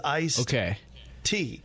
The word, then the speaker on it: iced tea: But for this, the funny thing to me is that I spell it one way and I pronounce it another. So if I ever write it, I definitely include the D because it is tea that has been iced. It is iced tea. But I never pronounce it when I say it.